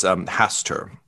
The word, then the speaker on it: Hastur: What do I know about Lord Hatzer? What can I? – And I'm sorry, I misspoke in the heat of battle. It's Hastur, H-A-S-T-U-R.